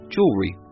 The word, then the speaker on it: jewelry: If you want to say it with an American accent, jewelry, two syllables, and spelled J-E-W-E-L-R-Y. And if you want to use the British spelling and pronunciation, then now you have three syllables. Jewelry, J-E-W-E-L-L-E-R-Y, jewelry.